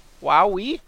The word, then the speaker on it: Huawei: I think I'm saying that right, because it's H-U-A-W-E-I? So, Huawei. Huawei? Yeah, that's Huawei.